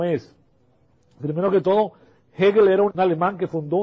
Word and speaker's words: Hegel: Este es el hombre, se llama, y se pronuncia así porque es alemán, se pone con H, pero la H no es silenciosa, es uno de los únicos casos en el español que la H no es silenciosa. Aquí se pronuncia Hegel en español, suavemente.